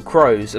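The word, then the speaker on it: Karous: I also left one off the list, Kairos, or Crows as I hear it pronounced.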